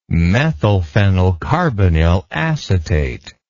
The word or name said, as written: methylphenylcarbinyl acetate